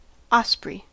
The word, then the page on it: osprey: American English